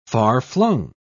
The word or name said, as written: far-flung